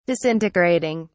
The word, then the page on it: disintegrating: British English